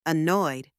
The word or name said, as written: annoyed